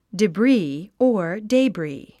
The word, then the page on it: debris: dəbríː; デブリィ